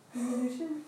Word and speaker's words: diminution: Diminution? – Diminution, not diminution. That's how usually people say it. Diminution, it's diminution.